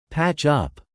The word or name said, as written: patch up